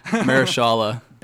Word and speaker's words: Mahershala: And, you know, also with Moonlight, Mahershala Ali, still can't pronounce that very well. – No. Mahershala.